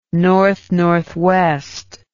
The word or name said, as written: north northwest